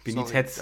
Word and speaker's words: Benitez: Äh, Benitez. – Benitez. Sorry, da ist die Betonung.